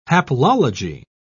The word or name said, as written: haplology